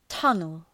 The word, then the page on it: tunnel: ‘tʌnəl